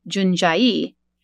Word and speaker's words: Jundiaí: In under an hour, I was in the town of Jundiaí, pronounced Jundiaí, at what looked like a stadium.